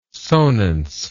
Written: sonance

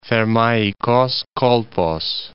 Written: Thermaikos Kolpos

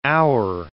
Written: our

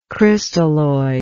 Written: crystalloidal

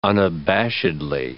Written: unabashedly

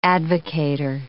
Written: advocator

